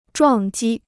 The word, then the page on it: 撞击: zhuàng jī